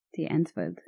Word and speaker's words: Die Antwoord: DIE Antwoord.